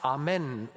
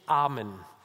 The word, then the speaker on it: Amen: Es heißt Amen und nicht Amen, wenn man es korrekt aussprechen möchte. im Hebräischen. Es heißt übrigens auch die Anrede, die Jesus für Gott hat, Abba und nicht Abba. Abba ist eine schwedische Popgruppe. Er erhebt also nach dem Essen diesen Kelch und dann geht dieser Kelch rum.